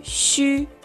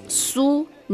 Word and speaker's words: xu: Si, yu, xu. En pinyin se pronuncia shu. No es su ni shu, sino shu. Shu.